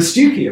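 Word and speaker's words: Stiffkey: And Stewkie, of course.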